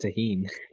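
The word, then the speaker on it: Tajín: If I'm not mistaken, it's pronounced Tajin. – Oh. – Because in Spanish words, in Spanish language, J is pronounced as an H.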